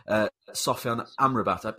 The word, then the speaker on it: Sofyan Amrabat: I'm very excited to discuss the player we've all come to talk about, which is, of course, a Morocco star, Sofyan Amrabat. I hope I've pronounced that right. – It's perfect.